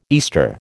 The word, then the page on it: Easter: American English